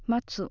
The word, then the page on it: matsu: Japanese